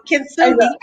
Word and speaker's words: Kintsugi: So Kintsugi, I hope I pronounced that right. – You did. Yeah. Good job.